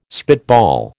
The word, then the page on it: spitball: American English